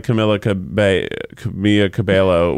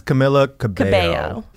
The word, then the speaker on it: Camila Cabello: Becky's not like Camila Cabello, which I don't. One of the L's you pronounce, ones you don't. – You pronounce Camilla, right? – I think it's Camila Cabello.